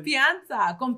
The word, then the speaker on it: confianza: Confianza. – Yeah, okay, you just gotta say it fast. Confianza, like all together. Confianza.